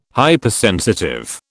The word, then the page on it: Hypersensitive: British English